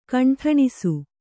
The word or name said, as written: ಕಂಟಣಿಸು